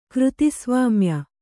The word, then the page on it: ಕೃತಿಸ್ವಾಮ್ಯ: křtisvāmya